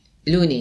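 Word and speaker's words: Lunae: Una era una Mazaretta giovanile in assemblaggio con Liguria di Levante IGT, Cantina Lunai. – Lune. In latino non si legge la... – Ah, quindi... – Questo è latino, lune. – Lune. – Lune, devi dire.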